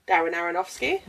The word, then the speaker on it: Aronofsky: Also, I feel like I pronounced his name wrong. – No, really good job. What did you say? – Darren Aronofsky. – That is it. That is how you say my man's name.